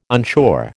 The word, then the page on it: unsure: American English